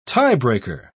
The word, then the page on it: tiebreaker: British English